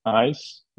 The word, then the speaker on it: AYES: But basically, we are from Belgium, and we started our company, which is called AYES, that's A-Y-E-S instead of E-Y-E-S, although we pronounce it the same.